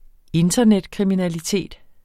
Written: internetkriminalitet

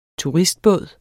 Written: turistbåd

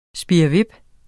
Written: spirrevip

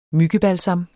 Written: myggebalsam

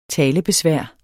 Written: talebesvær

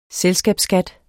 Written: selskabsskat

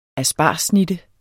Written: aspargessnitte

